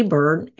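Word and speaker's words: Byrd: So you can find me, Sandy Bird.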